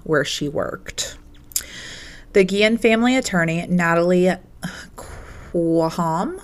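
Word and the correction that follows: Khawam: I hope I'm saying that correctly. – I think I heard in the thing that I listened to, it's Quam. – Quam. – Yeah, Quam.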